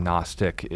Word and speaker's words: Gnostic: No, just clarifying, for those of you who aren't in on the term, Gnostic is a early Christian cult that believed in hidden knowledge. Think like the silent G in Gnostic. It's like gnome.